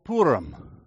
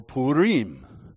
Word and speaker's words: Purim: So there is what is called a minor holy day, and it is coming up in a couple weeks, and it is called Purim, or Purim, however you want to pronounce it.